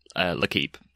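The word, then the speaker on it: L'Équipe: L'Equipe, but yeah. – L'Equipe. Whether you pronounce it. I will take your word for it. I'm no good at pronunciation. – I'm happy to call it L'Equipe for the rest of my life.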